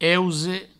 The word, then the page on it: euse: Provençal